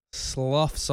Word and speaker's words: slough: No, skin peeling's different because the way it sloughs off, isn't that the worst word? Oh, that's the worst word. Slough. It sloughs and it's spelled like S-L-O-U-G-H, like slow, like dough.